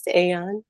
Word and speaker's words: Aeon: Aeon, how do you say that? – Yes, Aeon. Perfect.